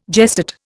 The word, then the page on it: gestate: Indian